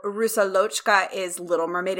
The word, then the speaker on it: Rusalochka: We're talking about uh, Rusaloka, is how it looks like it's spelled. They say Rusalka. Ru- oh, Rusalochka. Rusalochka is, I looked it up.